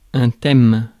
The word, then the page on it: thème: France; /tɛm/